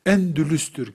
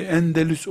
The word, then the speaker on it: Endülüs: Mesela Endülüs'tür kelimenin aslı. Türkçe'ye Endelüs olarak yerleşmiş. Mecburen yani yaygın hata kitaplardaki düzgünden daha muteber kabul edildiğinden Endelüs diyoruz. Aslı Endülüs'tür.